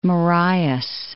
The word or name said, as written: Marias